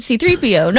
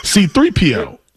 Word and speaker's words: C-3PO: C-3PO. Like, I don't know what you talk. – Your emphasis is on the C. – C-3PO. – Mine's is on the 3. Mine's on the 3. P. C-3PO.